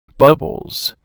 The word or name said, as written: bubbles